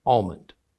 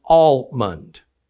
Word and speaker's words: almond: In most of America, the word is pronounced almond, but in some parts of America, the L sound is dropped, and it's pronounced almond. But in both areas, it's still spelled with the L in it.